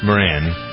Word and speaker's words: Moran: How'd you pronounce Moran. – That? Moran.